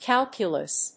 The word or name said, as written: calculous